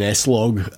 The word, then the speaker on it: S-Log: And been shooting in S-Log today and... – It's just pronounced slog.